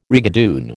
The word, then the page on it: rigadoon: American English